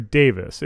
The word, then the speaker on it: Davies: Does he go by Davies or Davis? What is the pronunciation if you're Welsh? – It's Davis. – Davis, so. Okay, yes. So he's Russell T. Davies. – Russell T. Davies. Not Davies, but Davis.